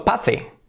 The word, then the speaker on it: pace: Ritmo escrito pace. Pace. Fijaos, se escribe pace, pero se escribe igual que carrera. Race. Y todos sabemos pronunciar carrera, que es race. Por lo tanto, ritmo, ¿cómo será? Pace. Race, pace. Race, pace. Sencillo, ¿verdad?